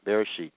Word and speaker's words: Bereshit: Let's go to Genesis, Bereshit, Genesis in Hebrew. That's what it means. Well, that's how it's pronounced.